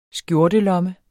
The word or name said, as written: skjortelomme